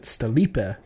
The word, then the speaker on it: Stlipa: They say they pronounce it Stalipa, but you can pronounce it how you like.